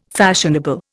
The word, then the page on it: fashionable: Indian